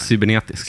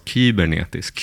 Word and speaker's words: cybernetisk: Cybernetisk. Cybernetisk. Kan också uttalas kybernetisk. Kybernetisk. Eftersom att det är grekiska.